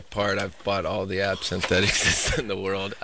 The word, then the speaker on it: absinthe: How do you say that? Absinthe? – Yeah, it's absinthe.